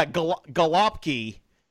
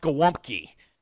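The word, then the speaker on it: gołąbki: so yeah, galopki, which is stuffed cabbage, galopki is how us English folk would say it. It's actually pronounced galumpki.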